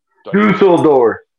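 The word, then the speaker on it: Düsseldorf: You had a qualifying event, so not everybody will be in Dusseldorf. Isn't that how you say it? – Dusseldorf. – What would Shrek there's say? There's like the two apostrophes the above dots, the U.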